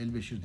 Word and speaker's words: Elbeşir: Anlamaya çalışırken Arda'yı da bu kız kızın bu kadının ahlam Albaşir, Al Elbeşir olması lazım bu değil mi herhalde? – Elbeşir diye okunuyor.